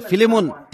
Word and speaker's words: फिलेमोन: त्यसैले फिलेमोन त्यहाँ पछाडि कसैले यसलाई यही भनेको थियो जुन मलाई लाग्छ वास्तवमै यो नै ठिक उच्चारण हो।